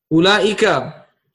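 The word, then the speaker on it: Ulaika (أولئك): And then I have finally Ooh, but this is like the wow is silent here, okay? The wow is silent. Ulaika, which means those.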